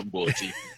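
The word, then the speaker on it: umqombothi: I'm not going to pronounce umkomboti. – Umkomboti. – Yeah, you got it.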